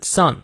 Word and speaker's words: sun: 啊，s u n son，没错，它的发音和儿子 son 是一模一样的，没有任何差别，它们是同音词。